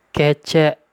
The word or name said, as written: Kécék